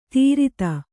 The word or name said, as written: ಧೃತಿ